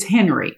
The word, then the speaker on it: Henri: And it's Henry. – Henry, it's as in Henrietta.